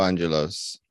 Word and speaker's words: Angelos: I guess the Greeks will call me Angelos, the European and international people will call me Angelo.